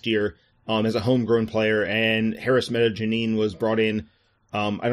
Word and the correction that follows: Medunjanin: It, is it a soft J? I got it wrong. Yeah, it's it's Medunjanin.